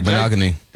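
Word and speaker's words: Igbinoghene: Nehemiah Pritchett and Noah Igbinogene. Did I say that right? – Igbinogene.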